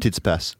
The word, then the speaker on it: Tsitsipas: Then after the game, Tsitsipas, am I saying that right? – Tsitsipas.